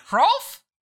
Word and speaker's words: Hrolf: Is the H silent, or is it? Does it you really roll into that R? – You really pronounce it. Hrolf, you know? Like, you're getting into it.